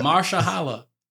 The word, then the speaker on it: Mahershala: Don't say his name then, but say Mahershala.